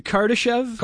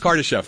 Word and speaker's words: Kardashev: or the Karadashov. – Hold on, the Kardashev? – Kardashev, thank you.